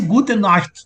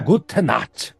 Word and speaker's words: Gute Nacht: Deve ser Gute Nacht, Nacht. É só você pensar Guten Morgen, Guten, não sei isso.